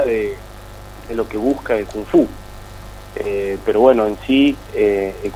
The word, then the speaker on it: Kung Fu: Kung Fu. Está bien, al menos pronunciado, ¿sí? – Sí, perfecto, perfecto.